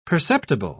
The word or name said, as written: perceptible